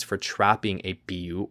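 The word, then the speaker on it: pillu: It's a short one, and it says the Wekufe also have the power to capture and enslave the Biu. This could also be pronounced Bilu. It's P-I-L-L-U,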